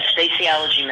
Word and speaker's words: Facialogy: The practice is called, is it Facialogy? I want to make sure I'm pronouncing that correctly. – Yes, Facialogy Medical.